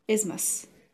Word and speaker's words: Isthmus: Isthmus.